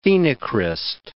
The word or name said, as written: phenocryst